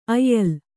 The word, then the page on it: ಆಯಾಲ: Kannada